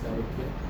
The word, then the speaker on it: Elohim: When Yud Kei Vav Kei is pronounced Elohim.